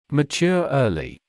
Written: mature early

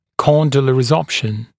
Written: condylar resorption

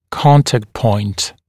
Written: contact point

